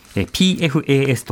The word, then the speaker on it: PFAS: PFAS と書いて PFAS なんですが、この PFAS っていうのは原田さん一体どういったものなんでしょうかはい。まあ、あの、いきなり PFAS と聞いても皆さんわからない。